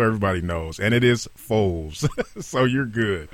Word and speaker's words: Foles: and there's I understand that an Eagles quarterback, I think you pronounced the gentleman's name, so it's Nick, is it Foles or Foley? – Foles. – F-O-L-E-S. – Yep. You said Foles.